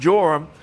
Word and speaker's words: Joram: So you can see these two with the same name both reigned together pretty much at a similar time, and their names are pronounced Joram.